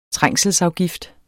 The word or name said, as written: trængselsafgift